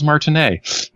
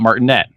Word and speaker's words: Martinet: It also made me realize his name is pronounced Charles Martinet, not Martinet, as I have heard in many places.